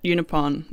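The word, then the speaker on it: Unaipon: Indigenous man whose first name is David and second name, is it Unipon? Is that how you pronounce it? – Yes, because I checked it. Unipon.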